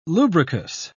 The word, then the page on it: lubricous: British English